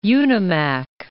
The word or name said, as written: Unimak